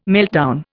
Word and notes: meltdown: Indian

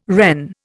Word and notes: Wren: Indian

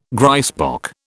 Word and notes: grysbok: British English

